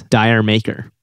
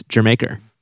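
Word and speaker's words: D'yer Mak'er: Jermaker by Led Zeppelin, which apparently a lot of people mispronounce Dire Maker. And that's not how it's pronounced. It's Jermaker.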